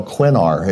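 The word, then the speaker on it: Cwenar: It's sinner like swinner, but we get Quinar. And then since nobody can say your last name, then they call me Noel like a girl. So I'm Noel Quinar is how most people know me.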